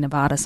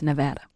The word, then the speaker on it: Nevada: We say Nevada sometimes, but it's Nevada.